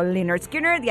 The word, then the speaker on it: Lynyrd Skynyrd: Y él me dijo, Lynyrd Skynyrd, y ahí dije, perfecto,